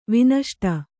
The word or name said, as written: ವಿನಷ್ಟ